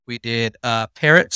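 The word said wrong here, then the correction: Perrot: State Park. Is that how you say it? – Perot, I think.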